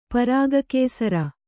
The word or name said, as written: ಪರಾಗಕೇಸರ